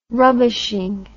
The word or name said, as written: rubbishing